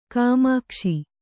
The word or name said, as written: ಗಮಕಿಸು